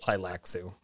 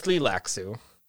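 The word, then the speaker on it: Tleilaxu: First of all, it's not even pronounced Tlilaxu. It's pronounced Tlilaxu. – Okay, that's according to Frank Herbert in his read-through. – No, so it's an E-I after that L.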